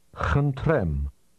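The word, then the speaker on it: Khntrem (խնդրեմ): Khntrem. Rem. Rem. Khnt. Khnt. Khntrem. Khntrem. – This word begins with a sound that is similar to the English H, but it's pronounced with a lot of breath. Say again, please.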